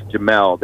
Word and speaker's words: Jamil: And basically, you can get in contact with me, just my full name, on Facebook or Instagram, and that's Jamil. That's spelled J-A-M-I-L, C as in Charlie, and then Winston, W-I-N-S-T-O-N.